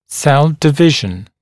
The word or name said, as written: cell division